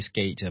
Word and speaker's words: SK8: SK8 呢即是滑板 ,skate 的英文鞋音縮寫啊 ,skate, skate 嘛。那個 ate 呢就跟數目字 8 的英文 a 啫就發音相同。